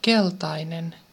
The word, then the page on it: gelb: [ɡɛlp]; /gɛlp/